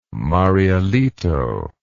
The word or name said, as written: Marielito